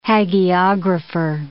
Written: hagiographer

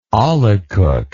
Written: olykoek

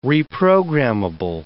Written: reprogrammable

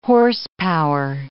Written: horsepower

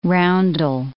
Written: roundel

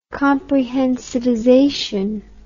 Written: comprehensivization

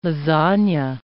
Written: lasagna

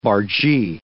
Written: bargee

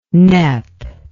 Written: nep

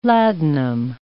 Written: ladanum

